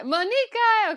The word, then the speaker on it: Muniqui: Did I say it right? – No, Muniqui.